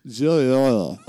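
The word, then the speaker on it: joueur: Is it J-O-U-O-U-O-U-O-U? – You're so close. You're so close. It's J-O-U-E-U-R. Joyeux.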